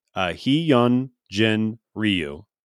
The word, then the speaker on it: Hyun Jin Ryu: His name is pronounced Hyun Jin Ryu.